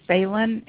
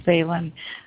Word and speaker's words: Phelan: My last name is pronounced Phelan. It's an Irish Catholic name.